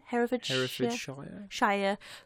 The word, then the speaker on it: Herefordshire: Herefordshire Folk Tales, written by David Phelps.